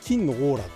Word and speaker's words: 黄金: 黄金と書いて「金のオーラ」って読ませる